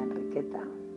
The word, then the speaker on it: Enriqueta: la Enriqueta, porque se dice así en catalán, la Enriqueta